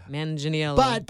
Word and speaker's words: Manganiello: Manganiello. Manganiello? – I think so. – Like Chuck Mangione? Manganiello.